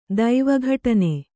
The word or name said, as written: ದೈವೀಘಟನೆ